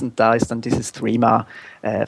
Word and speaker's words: Threema: Also es wird äh, Threema ausgesprochen, eigentlich wie Englisch äh, Three, einfach noch mit MA hinten dran.